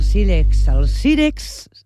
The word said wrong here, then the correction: Sírex: A veure si ho pronuncio bé. Sírex.